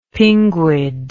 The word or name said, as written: pinguid